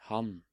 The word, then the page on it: han: Norwegian